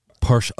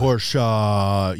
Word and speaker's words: Porsche: It's pronounced Porsche. Porsche. Uh, Porsche.